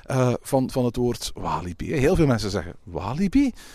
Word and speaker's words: Walibi: En laten we heel eerlijk zijn, als je het in, op zijn Frans uitspreekt, want oorspronkelijk ligt Walibi natuurlijk in, in het Franstalige deel van België, dan is het Walibi.